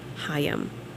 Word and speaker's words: Haim: I got a text message from Emily, who is I've been talking about the whole show, and Emily said it's pronounced Haim.